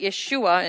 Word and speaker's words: Yeshua: So when Yeshua said, Jesus in English, but Yeshua in his original pronunciation, had said these words, they were very upset, especially the priests, the Jewish leaders.